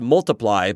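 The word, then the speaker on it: ×: It is pronounced Multiply, but it's just the time symbol, that little X.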